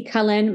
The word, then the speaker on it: Cullen: Stalk me, please, at the Lucy Cullen. My last name is spelled like, the people in Twilight for all you millennial folks. It is my real name. I've had that question asked. At the Lucy Cullen on Instagram and TikTok.